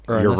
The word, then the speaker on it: Uranus: Uranus. Uranus. – Or Uranus. – No. – Uranus. – Your. Say it after me. Your. – You. – Anus. – Anus.